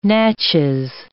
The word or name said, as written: Natchez